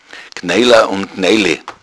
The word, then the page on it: knoedl: bayerischen Dialekt